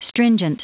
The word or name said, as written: stringent